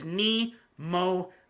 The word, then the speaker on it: Nemophila: This is Nemophila, by the way. From some of the videos, yes, some people told me how to pronounce it. It is Nemophila, which means like cute and deadly or cute and something, I think.